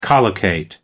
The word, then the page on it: collocate: American English